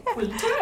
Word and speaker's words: Pulter: um, Hester Pulter. – Am I saying that correctly? I like the way you say it. Oh. I mean, I don't think anyone knows, so I think we can say Poulter.